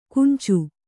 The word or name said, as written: ಕುಂಚು